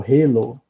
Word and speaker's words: Halo: ou Halo. Alguns pronunciam assim, Halo, outros Halo.